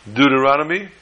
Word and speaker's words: Deuteronomy: Deuteronomy.